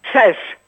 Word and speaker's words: sheesh: Później jest shish. Czy szesz? – Szesz. – Szesz. – Wymawiają szesz. – S-H-E-E-S-H.